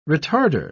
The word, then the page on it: retarder: British English